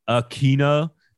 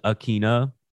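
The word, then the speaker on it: Ikhine: Nick Westbrook, Akina, apparently is how you pronounce it. Akina.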